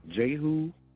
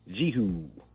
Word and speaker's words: Jehu: Jehu, Jehu, a long E.